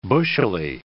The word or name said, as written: bushily